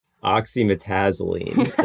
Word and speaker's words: oxymetazoline: And it's oxymetolazine, which is, I didn't say it right. Say it right. – Oxymetazoline is how I think it's pronounced.